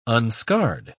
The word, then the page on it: unscarred: British English